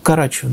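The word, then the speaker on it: Карачёв: Ну, ударение на второй А. – Да? – Карачев, да. Ну, с другой стороны, там есть буква Ё. Вот, поэтому на Карачев.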